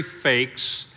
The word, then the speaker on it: Feix: So my name is Terry Feix, and you would need inspiration of the Holy Spirit to look at my name and pronounce it correctly. So it's pronounced like F-A-K-E-S, and I'm one of the pastors on staff here at Crossings.